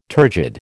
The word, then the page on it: turgid: American English